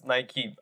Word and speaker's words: Nike: Yeah, I think, like, I like to reference Nike a lot.